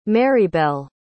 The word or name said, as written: marybelle